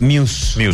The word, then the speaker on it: Muse: Yo dije Mouse, una que se llama, leí mal, pero sí, era Muse. – Es Muse. Muse es la pronunciación oficial.